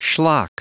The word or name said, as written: schlock